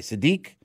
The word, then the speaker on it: Siddiqi: No, how do you pronounce it? Sadiq? S-I-D-D-I-Q-I. Sadiq and Jack Rizzuto.